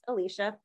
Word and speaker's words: Alysha: My name is pronounced Alicia Myronic.